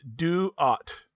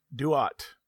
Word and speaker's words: Duat: It's Duat. Duat. – How you spell it? – D-U-A-T.